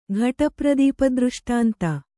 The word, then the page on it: ಘಟಪ್ರದೀಪದೃಷ್ಟಾಂತ: ghaṭapradīpadřṣṭānta